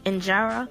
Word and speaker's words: injera: And it's traditionally served with authentic injera, which is spelled I N J E R A, which is an Ethiopian flatbread.